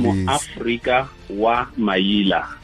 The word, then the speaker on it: Moafrika Wa Manila: Yes, you probably butchered my name and surname. I am Moafrika Wa Manila. It's a very surname. Properly pronounce it.